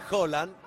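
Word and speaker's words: Haaland: Jorge, déjame responderle a la gente la inquietud de la pronunciación de Holland. Si bien se escribe con doble A en noruego, su correcta pronunciación es precisamente con O. Digo, como si se tratase de Holanda.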